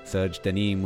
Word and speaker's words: Serge DeNimes: We're also going to have a chat with Oliver Proudlock, founder of Serge Denim Serge Denim with a silent S on the end.